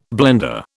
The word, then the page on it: blender: British English